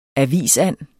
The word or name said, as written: avisand